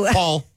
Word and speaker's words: Poll: P-O, it's pronounced Paul, but it was spelled P-O-L-L, as in pole.